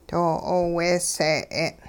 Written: doo-'ooweesee'e'n

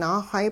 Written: naaxay

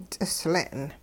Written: ts'isle'n

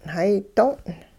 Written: hay do'ong'

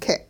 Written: -ke'

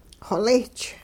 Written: xolich

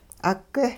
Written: 'ugeh

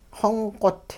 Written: xongqot

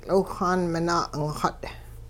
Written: tL'iwhxaan minaa' 'ingxut

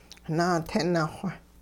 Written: naatini-xw

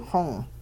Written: xong

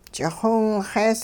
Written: ch'ixiwinxees

